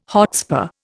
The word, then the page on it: hotspur: Indian